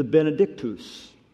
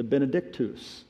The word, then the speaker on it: Benedictus: Now, traditionally, in the history of the church, this song found in verses 67 through 79, which the crafts read, has been called the Benedictus, the Latin pronunciation, the Benedictus, or the blessing.